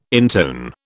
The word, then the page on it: intone: British English